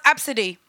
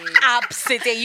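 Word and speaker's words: Abcde: It's pronounced Absidy. – Oh, that's ridiculous. Absidy. You could have put a vowel Absidy.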